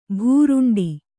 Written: ಭೂರುಂಡಿ

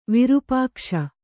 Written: ವಿರುಪಾಕ್ಷ

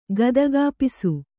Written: ಗದಗಾಪಿಸು

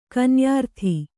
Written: ಕನ್ಯಾರ್ಥಿ

